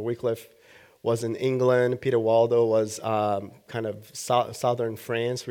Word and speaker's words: Waldo: The first one is Peter Waldo, and then, or Valdo, that's actually how it's supposed to be pronounced, and John Wycliffe or Wycliffe.